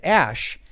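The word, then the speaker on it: Æ: It may look more like a math equation than a name, but the character E is apparently said as Ash. Well, I looked up on Wikipedia. I've never heard it pronounced that way, and they say it's most typically pronounced as E. Or you know, EE or something.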